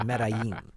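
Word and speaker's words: Medellín: If you watch Narcos, you know how to pronounce that. It's Medellin.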